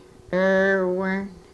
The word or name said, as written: rrwrn